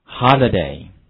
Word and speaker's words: holiday: 好，首先跟我读一下这个词的读音，注意字母 I 发的是 e 的音，跟我读一下 holiday， holiday。